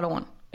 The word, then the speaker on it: Owain: O-W-A-I-N. I feel like it's... – Owen. – O-W-A-I-N. – O-W-A-I-N. Yeah, some people do pronounce that Owen. – It's Welsh, I'd say, for Owen, yeah.